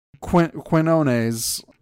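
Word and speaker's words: Quiñones: They had Magdalene Visaggio, Joe, how do I pronounce that? Joe Quinoa? I don't know. – Quinoa, delicious. – Quinones, maybe? I don't know. – Quinones, pardon me.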